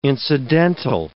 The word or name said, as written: incidental